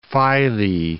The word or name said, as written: Philae